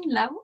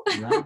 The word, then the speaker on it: Lao: Once we were in the truck uh, of a Chinese guy, and we were talking to him in uh, Lao. How do you say it? Laotian, Lao. Lao yeah.